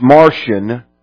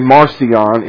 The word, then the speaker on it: Marcion: And the reason they gathered them together was because a heretic, and I call him Marcion, but he's pronounced Martian, but when you say Martian, people think he came from Mars, so I say Marcion, M-A-R-C-I-O-N.